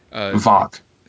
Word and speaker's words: Voq: I mean, we met her before in the first two episodes, but basically now she's Tox. Am I saying that right? Tox, isn't that his name? – Vok. – I'm sorry. – V-O-Q. – V-O-Q, yeah.